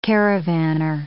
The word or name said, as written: caravaner